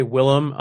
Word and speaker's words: Wilhelm: I usually just say Willem. I just decided on a weird spelling for it. But yeah, I usually say Wilhelm Iverson. That's how I pronounce it.